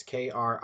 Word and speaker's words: krisis: It looks like crisis, Krisis with a K instead of a C, but it's pronounced krisis.